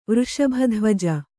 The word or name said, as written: ವೃಷಭಧ್ವಜ